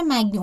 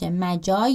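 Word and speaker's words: Magi: هفته پیش من اسم یکی از آثار بوتیچلی رو به اشتباه ستایش مگی عنوان کردم که تلفظ درستش ستایش مجا یا ها بود.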